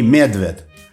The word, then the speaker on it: Медвед: Кроме того, у них были фамилии с одним корнем – Бернстайн, Берлага, Берландер и Медвед с ударением на первом слоге, но на это никто не обращал внимания.